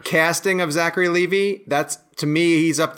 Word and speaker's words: Levi: And I was told it's Levi like the jeans.